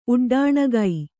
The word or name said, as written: ಉಡ್ಡಾಣಗೈ